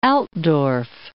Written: Altdorf

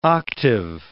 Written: octave

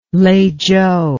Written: Leizhou

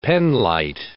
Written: penlite

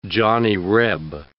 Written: Johnny Reb

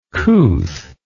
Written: couth

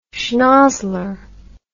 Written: schnozzler

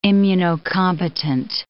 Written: immunocompetent

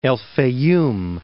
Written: El Faiyum

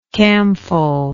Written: camphol

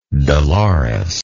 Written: delores

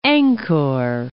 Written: Angkor

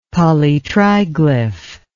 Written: polytriglyph

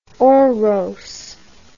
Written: oros

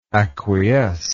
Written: acquiescingly

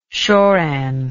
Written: shoran

